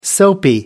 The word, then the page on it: soapy: American English